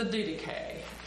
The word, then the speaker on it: Didache: It's a wonderful word that is really, I think, pronounced the Didache.